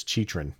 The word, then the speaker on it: Chychrun: And it's not pronounced how it sounds. Jacob Chychrun. Pretty sure it's Chychrun. It's just one of those last names that the way that it sounds, it's completely different, which is cool.